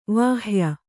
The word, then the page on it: ವಾಹ್ಯ: vāhya